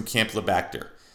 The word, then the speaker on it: Campylobacter: It's Campylobacter.